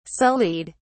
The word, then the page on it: sullied: British English